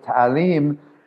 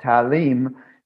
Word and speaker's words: Talim: Talim is the English way of pronouncing it. We like to pronounce it Talim in Morocco. It does not transliterate very well. Talim is the Arabic word for education.